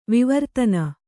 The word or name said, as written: ವಿವರ್ಧನ